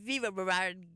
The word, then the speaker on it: Viva Brevard: We've got the Brevard Hispanic Center is inviting you to the Viva Brevard Gala. Did I say that right? – Yeah, you did it.